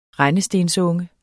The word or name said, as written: rendestensunge